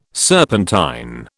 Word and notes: serpentine: British English

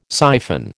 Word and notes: syphon: American English

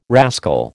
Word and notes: rascal: American English

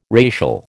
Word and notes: racial: American English

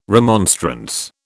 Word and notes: remonstrance: British English

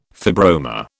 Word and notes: fibroma: British English

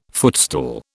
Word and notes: footstool: British English

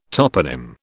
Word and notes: toponym: British English

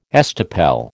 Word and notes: estoppel: American English

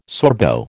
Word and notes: Sorbo: American English

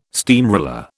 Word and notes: steamroller: British English

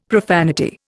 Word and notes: profanity: Indian

